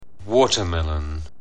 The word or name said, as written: watermelon